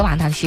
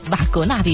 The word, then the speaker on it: 瓦格纳: 嗯，据说他特别喜欢格瓦纳的一些瓦格纳的一些歌剧。